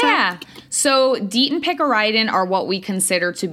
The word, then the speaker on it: picaridin: Next in my arsenal against ticks, thanks to Andrea, I have DEET and I have Picardin? How do you say it? – Picaridin. – Wow, I was close.